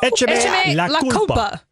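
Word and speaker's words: Échame la culpa: Well, you just said the accent in the wrong syllable.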